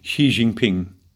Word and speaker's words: Xi Jinping: Naja, Sie könnten eigentlich, das ist eigentlich meine Frage, nur antworten, was Herr Xi, Xi, Xi, Xi, Xi Xi, Jinping Bitte Xi Jinping, ist egal, sagen Sie Xi, das sagen wir, so spricht man es in Deutschland aus.